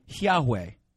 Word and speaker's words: Yahweh: So we would pronounce God's name Yahweh.